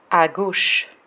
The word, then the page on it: a gauche: French